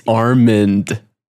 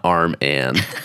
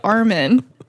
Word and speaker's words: Armand: It's Armand. – Yes. – A-R-M-A-N-D, not Arm-Ann. – Like, but you say it like Armin.